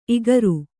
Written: ಇಗುರು

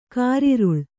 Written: ಕಾರಿರುಳು

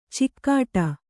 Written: ಚಿಕ್ಕಾಟ

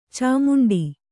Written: ಚಿಮ್ಮಂಡೆ

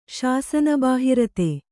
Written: ಶಾಸನಬಾಹಿರತೆ

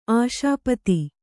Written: ಆಶಾಪತಿ

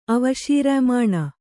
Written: ಅವಶೀರ್ಯಮಾಣ

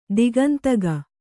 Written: ದಿಗಂತಗ

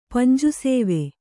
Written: ಪಂಜುಸೇವೆ

